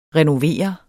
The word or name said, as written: renovere